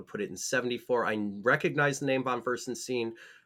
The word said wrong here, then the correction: von Furstenberg: Von Furstenberg.